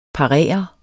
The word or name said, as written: parere